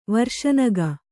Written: ವರ್ಷನಗ

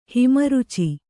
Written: ಹಿಮರುಚಿ

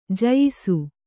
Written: ಜೋಯಿಸ

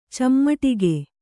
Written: ಚಿಮುಟಿಗೆ

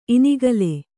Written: ಇನಿಗಲೆ

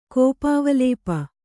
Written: ಕೋಪಾವಲೇಪ

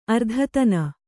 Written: ಅರ್ಧತನ

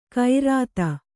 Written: ಗೈರತ್

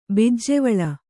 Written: ಬಿಜ್ಜೆವಳ

